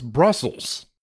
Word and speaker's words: Bruxelles: It's a re-release of a classic Euro game called, do you know how to pronounce Bruxelles? Oh, so it's Brussels.